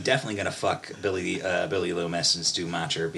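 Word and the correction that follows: Macher: because, am I saying that right? – Is it Mocker? – I think it was Mocker.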